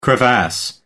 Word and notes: crevasse: American English

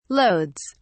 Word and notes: loads: British English

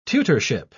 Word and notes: tutorship: British English

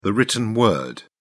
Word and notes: written word: British English

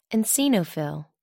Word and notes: eosinophil: American English

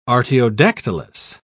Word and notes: artiodactylous: British English

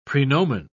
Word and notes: praenomen: British English

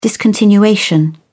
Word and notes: discontinuation: British English

